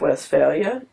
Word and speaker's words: Westphalia: And I was very much struck by the story of the Peace of Westphalia.